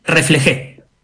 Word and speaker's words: reflejé: que obviamente refleje, ¿cómo se dice? ¿Está bien? Refleje, ¿sí? – Reflejé.